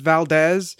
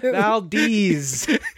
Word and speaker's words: Valdez: It's Valdez. In parentheses, they spell the pronunciation, and they spell it Valdez. Valdez.